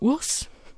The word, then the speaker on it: WRS: Der Künstler schreibt sich in Großbuchstaben WRS, ausgesprochen wird es aber Urs, denn äh, der Sänger heißt Andrei Ursu,